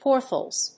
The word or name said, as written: port holes